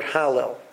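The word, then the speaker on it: halel (הלל): So, but there's a negation on the lamed, so we pronounce it halel. So really, it's hal, and then lel.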